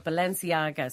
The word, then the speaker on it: Balenciaga: Well, I mean, I I'm not in the demographic, but Balenciaga.